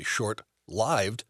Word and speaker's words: short-lived: Therefore, the L-I-V-E-D in short lived, mm-hmm. must be referring to the noun life as opposed to the verb live. So it should be pronounced short-lived.